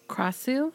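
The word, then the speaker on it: Krasue: According to a Thai person, they were saying that the Kraus, I hope I'm saying this right, it's K-R-A-S-U-E. – Krasu? – Krasu. The Krasu.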